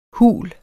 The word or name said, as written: hul